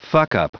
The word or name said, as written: fucker